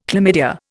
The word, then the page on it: chlamydia: Indian